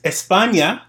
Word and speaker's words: España: España. – Oh, look, he pronounced it correctly.